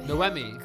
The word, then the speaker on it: Noemi: You almost got it. It's Noemi. – Noemi. – Noemi, yeah. It's the French version of Naomi, so it's a little tricky. – Noemi. That's better.